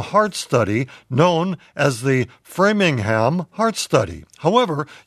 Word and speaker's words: Framingham: But Framingham is pronounced framing, as in one framing a picture, and ham, as in a Virginia ham.